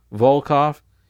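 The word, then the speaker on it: Volkow: N-O-R-A, Volkov. That's how she pronounces it, is V-O-L-K-A-W.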